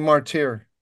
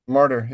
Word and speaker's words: martyr: You keep saying martyr. – And you should say martyr? – Martyr, yeah. – Martyr? Martyr? – Yeah.